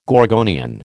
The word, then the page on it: gorgonian: American English